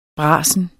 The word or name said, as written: brasen